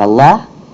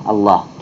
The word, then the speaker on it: Аллах: Но почему Аль-Иля произносится как Аллах? Это Хамза Аль-Иля. Хамза убирается для того, чтобы легче было произносить.